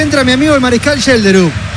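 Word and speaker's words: Schjelderup: Y entra el número 21, Yelderup. Vamos a decir Yelderup. – El mariscal Yelderup.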